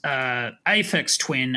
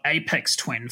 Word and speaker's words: Aphex Twin: I only recently discovered that it's Aphex Twin and not Apex Twin.